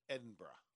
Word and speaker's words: Edinburgh: Edinburgh. – Uh-huh. – Properly pronounced.